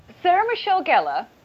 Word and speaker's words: Sarah Michelle Gellar: Sarah Michelle Gellar, yeah. – I said this right. – Yeah. – Sarah Michelle Gellar.